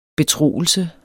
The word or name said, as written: betroelse